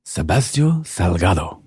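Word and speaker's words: Sebastião Salgado: You got to say his name right. – Is it not Sebastio? – No, that's fine, but I need the accent. – Sebastio Salgado.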